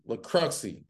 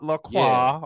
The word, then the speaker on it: Lacroxi: Yes, LaCruxy. – Yes, LaCruxy. I'm glad you said it right. Not LaCroix or LaCroix.